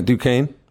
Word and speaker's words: Duquesne: Yeah, Duquesne.